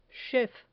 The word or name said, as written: chef